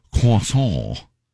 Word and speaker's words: croissant: I mean, uh, it, the French way to say it is uh, croissant. – Yes, it is. – So there is the silent T and a silent uh, S and a silent A and a silent C and a silent R.